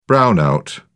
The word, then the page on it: brownout: British English